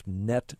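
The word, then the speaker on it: .NET: Someone writes this: I keep getting a pop-up that says there's some problem with Microsoft Net Framework. I assume that NET, that's intended to be pronounced as a word.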